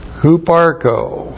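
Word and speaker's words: Huparko (ὑπάρχω): Huparko, first of all. H-U-P-A-R-C-H, long O. Huparko. Huparko literally means to exist, to be present.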